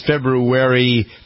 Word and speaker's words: February: A week from today is February, is how you pronounce it, because that's how it's spelled.